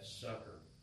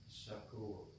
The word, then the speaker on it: succor: I had a fellow that teached me years ago and helped me because people pronounce that sucker, and it's succor. – Succor. Yeah. Right. – So that way you're not being suckered. Yes. You're being succored. – Succored. – Well, sucker is S-U-C-K-E-R.